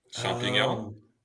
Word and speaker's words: champignon: Champignon. – Is it the same? – What is it? – What do you mean? – It sounds like champions. Or champagne. – Oh, champignon.